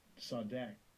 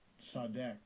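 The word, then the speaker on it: Tzedek (צדק): Let's hear the pronunciation of the root word. – Strong's H, 6663. Tzedek. Tzedek.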